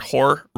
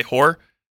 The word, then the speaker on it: horror: If anyone doesn't know that, I cannot pronounce the word horror right. Horror. That's how you're supposed to say it, but I say whore, like a prostitute, but that doesn't sound like prostitute whore in my mind.